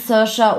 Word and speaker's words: Saoirse: Also ich liebe Saoirse, wie spricht man sie aus? – Saoirse, glaube ich. Saoirse. Also ich habe es eben gegoogelt, how to pronounce. Es kam Saoirse Ronan raus, genau.